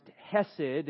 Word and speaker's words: hesed: That Hebrew word for kindness is referred to or is pronounced hesed,